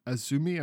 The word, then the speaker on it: Azumi: Azumi, I think, is how it's supposed to be pronounced, but it's just AZM is the way she writes that.